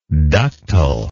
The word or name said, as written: ductileness